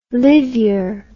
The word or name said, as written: livyer